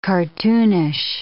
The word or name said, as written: cartoonish